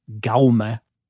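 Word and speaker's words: Jaume: But as far as I can tell, the J would be pronounced as a G. And so an approximation of it. So the first name would be like Gaume. – A hard G. – Yeah, like, so the English pronunciation would be like the G in rogue.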